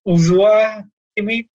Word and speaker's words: Joshua: pronunciations, like I always said it was Joshua Kimmich, but it's actually Uzua Kimmich.